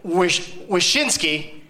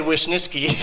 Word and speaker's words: Wyszynski: It's Greg Wyszynski. Said it right. I always want to say Wyszynski. – That's it. It's fine.